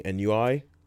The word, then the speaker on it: ennui: is E-N-N-U-I, ennui, ennui? – Ennui.